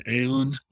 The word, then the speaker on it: Aon: You can find me either by my name, Orion Aon, last name is A-O-N, or Forage Colorado.